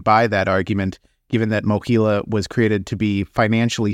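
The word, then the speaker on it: MOHELA: Thank you for pronouncing Mohila correctly.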